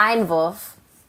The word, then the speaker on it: Einwurf: Like, wait, how do you say it though? Einwurf? What is it? – Einwurf. – Einwurf? – Einwurf. – Oh gosh, I have the worst American accent. I'm Einwurf. Oh, well, Einwurf.